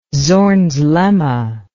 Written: Zorn's lemma

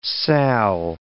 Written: sal

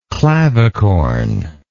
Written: clavicorn

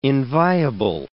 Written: inviable

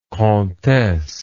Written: comtesse

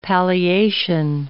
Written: palliation

